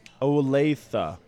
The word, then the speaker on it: Olathe: Olathe.